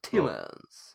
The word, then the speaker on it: Tielemans: But I don't think they'll get Tielemans back or Tielemann or however they pronounce it. – Tielemans.